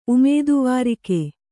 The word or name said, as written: ಉಮೇದುವಾರಿಕೆ